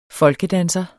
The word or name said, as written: folkedanser